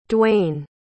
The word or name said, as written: Dwain